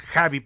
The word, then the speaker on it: Xavi: En realidad es Javi para los mexicanos o Xavi, como lo pronuncian en, algunas, en algunos idiomas, como en el catalán.